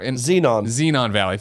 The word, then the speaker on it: Xenon Valkyrie: I put on, how would you say it? Exeon Valley? I can never. Xenon Valley. Thank you.